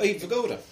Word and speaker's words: Vigoda: Oh, Abe Vigoda.